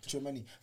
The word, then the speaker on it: Tchouameni: Tchouameni.